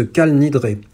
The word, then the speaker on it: Kal Nidré: comme dans ce Kal Nidré. J'utilise ici intentionnellement la prononciation séfarade comme le faisait Hemsi.